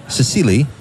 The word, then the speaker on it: Cecilie: Cecile to her friends. – Cecilie, isn't she? – Sorry.